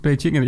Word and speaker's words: Beijing: Je hebt natuurlijk al de Olympische Spelen, zeg maar, Beijing. Zeg ik dat goed? Zeg maar, ja, volgens mij is het Beijing.